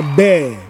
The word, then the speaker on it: bear: And then the animal is pronounced bear, bear.